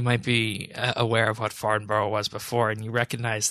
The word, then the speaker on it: Farnborough: It's Farnborough. British people don't pronounce half their vowels. It's Farnborough.